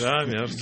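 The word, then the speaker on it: Jamerson: Джеймс, Джеймс обычно Джемерсон, как виски Джеймсон, только R в середине слова. – Вот. – То есть Джамерсон.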